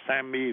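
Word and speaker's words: SAMe: Thuốc tên là SAMI, viết là S-A-M với cái chữ E nhỏ. Từ người Mỹ họ đọc là SAMI,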